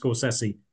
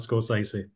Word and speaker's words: Scorsese: It's pronounced Scorsese, not Scorsese, just so.